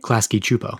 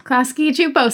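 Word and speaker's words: Klasky Csupo: The Klasky Kupo people. Did I say it right? – Klasky Chupo. – Klasky Chupo. See, I told you.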